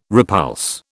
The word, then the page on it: repulse: British English